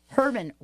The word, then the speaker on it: Herban: It's like herb, like the herb. You know, as Olivia Newton-John pronounces it, Herb. – Exactly. – So it's Herban Wellness, just for all of you, H-E-R-B-A-N, Wellness.